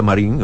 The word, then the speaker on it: Marine: Se escribe Marine, se pronuncia Marín, a veces le pone la E,